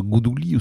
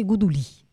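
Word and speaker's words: Godolin: Alors, la prononciation à l'ancienne, la façon de l'occitan ancien, c'est Godelin. Maintenant, la prononciation à la française, c'est Godelin.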